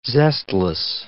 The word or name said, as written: zestless